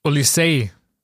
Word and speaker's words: Olise: So wird er übrigens ausgesprochen. Ich habe mich nochmal kundig gemacht. So hat er sich zumindest bei Crystal Palace vorgestellt. Er ist ja auch gebürtiger Engländer. Ja, also nicht Olise und auch nicht Olise, sondern Olisey, englisch ausgesprochen.